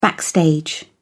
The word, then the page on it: backstage: British English